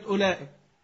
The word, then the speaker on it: أولئك: كلمة أولئك بعد الهمزة الأولى في إيه؟ واو. مش بنكتب واو ولا بنكتب أولى؟ طب إيه لازمة الواو دي؟ ها؟ هل لها لازمة في النطق؟